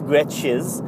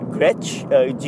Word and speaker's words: Gretsch: And one of them is, if you're into guitars, they're both Gretsches. I don't know how to pronounce it. Gretsch, G-R-E-T-S-C-H, Gretsch, and Gretsch.